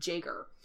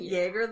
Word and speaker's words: Jaeger: So some people call him Yeager, and some people call him Jaeger. – Isn't Yeager the proper pronunciation? – Yes, because Yeager is German for hunter, and he is Germanic, as we learn in the episode.